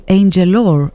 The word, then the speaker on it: Angelor: Αυτή ήταν η Angelor, έτσι πρέπει να προφέρεται, Γάλλοι είναι.